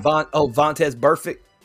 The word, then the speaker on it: Vontaze Burfict: Ever since he got hit in the head by Burfitt or Burfitt, whatever how you pronounce that super last name. – Vontaze Burfict.